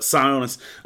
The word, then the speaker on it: Sionis: It gives us a nice little childhood backstory on Roman Sionisus. Is that how you say his name right? Yeah, Sionisus.